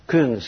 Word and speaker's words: Küng: En este caso los personajes son Vittorio Messori, un periodista italiano que ha escrito cosas muy interesantes, y Hans Küng, se pronuncia, un teólogo alemán.